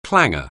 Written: clanger

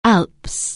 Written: Alps

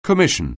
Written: commission

